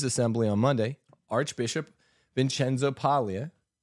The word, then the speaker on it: Paglia: And yes, I know, you really say his name is Paglia. We're American, we say Paglia.